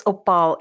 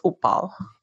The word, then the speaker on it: Uppal: Oh, it's Upal. It's spelled U-P-P-A-L. – Oh, uh-huh. – And the U is more of an U and yeah. not an U, so it's Upal.